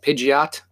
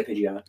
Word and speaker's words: Pidgeot: I always have Pidgeotto and I always evolve it into, now how do you say it? Pidgeot? Pidget? – I say Pidgeot. – I say Pidgeot too.